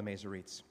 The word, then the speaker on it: Masoretes: And these are Hebrew texts preserved by scholars called the Masoretes, how it's pronounced.